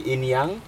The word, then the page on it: yag: Aneityum